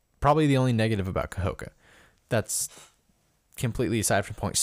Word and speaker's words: Kahoka: I always, that syllable, it's Kahoka. It's three syllables. And I always like, I say it in one big breath.